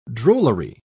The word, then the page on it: drollery: British English